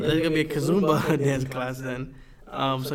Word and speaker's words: Kizomba: But I think it's Kizomba. – Kizomba.